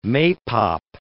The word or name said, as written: maypop